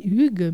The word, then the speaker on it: hygge: On dit hygge, mais ça s'écrit H-Y-G-G-E.